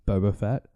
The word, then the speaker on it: Boba Fett: See, I keep getting Boba Fett and Boba Fett. Is Bubba? – You're getting the name wrong of Bubba. It's not. It's just Boba Fett.